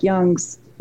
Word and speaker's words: Jung: And so it's pronounced Jung, but J-U-N-G.